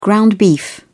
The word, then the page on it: ground beef: British English